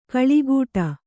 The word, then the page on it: ಕಳಿಗೂಟ: kaḷigūṭa